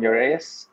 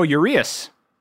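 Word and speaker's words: Urías: Ramon Urias. How do you say his last name? – Oh, Urias.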